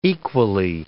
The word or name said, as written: equally